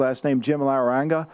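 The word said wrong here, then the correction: Larrañaga: Yeah, Laranaga. Yeah.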